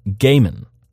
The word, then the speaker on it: Gaiman: Neil Gaiman is pronounced Gaiman, mm-hmm. not Gaiman. – Okay. – So, think it's gay men... not Guy Man, because Guy Man would be redundant.